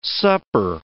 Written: supper

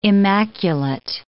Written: immaculate

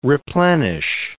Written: replenish

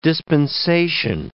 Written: dispensation

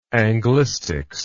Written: anglistics

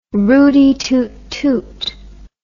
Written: rooty toot toot

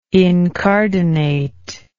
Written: incardinate

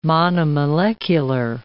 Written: monomolecular

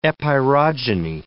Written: epeirogeny

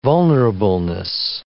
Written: vulnerableness

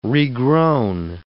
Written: regrown

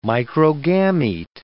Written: microgamete

